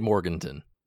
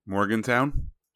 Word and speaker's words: Morganton: How would you say Morganton? Morgantown. Right. There's no. There's no W.